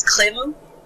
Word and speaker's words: Khemu: It's not hard to pronounce, but it's weird to pronounce. His name is Khemu. K-H-E-M-U.